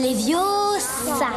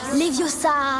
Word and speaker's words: Leviosa: Et puis d'ailleurs, tu ne sais même pas le prononcer. C'est Leviosa. Et pas Leviosa.